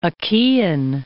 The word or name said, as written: Achaean